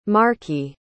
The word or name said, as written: markey